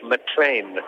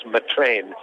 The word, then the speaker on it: McClean: And it's good to see a player called James McLean, and that's how you pronounce it, even though it's spelled M-C-C-L-E-A-N. It's actually pronounced James McLean, and he's actually born in Northern Ireland.